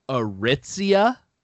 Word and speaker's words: Aritzia: Aritzia.